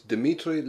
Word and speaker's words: Dimitri: Yes, it's Dmitry Levenko. – I'm sorry, but could you spell that for me, please? – Dmitry. D-I-M-I-T-R-I.